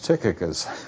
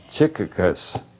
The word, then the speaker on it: Tychicus: Now, he didn't pronounce it the way I pronounce it, but I'm going to pull rank on him, and he's not here, so I say it's Tychicus. I think he said something like Tychicus.